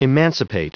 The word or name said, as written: emancipate